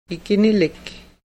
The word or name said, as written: ikinilik